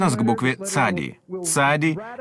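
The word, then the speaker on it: цади (צדי): Звук «ц» похож в ней на тот, что в слове «пицца». – Скажите «пицца», «ца», «цади». Вот как нужно произносить эту букву.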